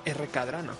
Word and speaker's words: Rkdrano: Pero te tengo que decir que eres de las pocas personas que ha pronunciado bien mi nick o mi pseudonombre en internet. Porque de normal el 90% de las personas es Racadrano, es R. – Cadrano. No saben pronunciarlo, pero creo que lo has pronunciado bien desde el principio.